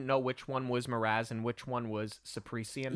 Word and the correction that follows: Caspian: Or so Caspian. Caspian is his name.